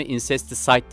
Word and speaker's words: Incesticide: Sevgili dinleyiciler, e, bir türlü söyle, söyleyemedi, söylememiş olduğum toplama albümün ismi Incesticide diye okunuyormuş. Ee, az önce baktım ben telaffuzuna. Incesticide ilk 1992'de e, yayınlamış oldukları bir toplama albüm.